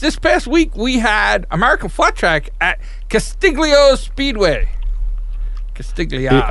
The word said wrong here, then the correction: Calistoga: Oh, Calistoga.